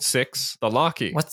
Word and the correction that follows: Loki: Loki? – Yeah, I guess if that's how you pronounce it.